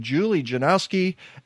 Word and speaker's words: Janoski: And then the person on the right, Julie Janoski.